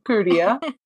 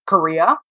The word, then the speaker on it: Curia: And then from there, took another train to Curia, C-U-R-I-A. Got to be easy on the pronunciation, Curia. I confused it for a little while. I was definitely pronouncing Korea. So it's Curia.